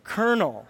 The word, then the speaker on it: colonel: Colonel. It doesn't follow the rules. It actually follows French pronunciation rules, not English ones.